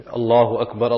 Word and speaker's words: Allahu Akbar: And then when pronouncing it, one should not make a mistake of lengthening the for example, Allahu, or you lengthen the lam, Allahu, or the word Akbar, you say Allahu Akbar. So these are the incorrect pronunciations.